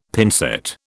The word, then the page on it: pincette: British English